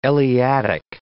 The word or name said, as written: Eleatic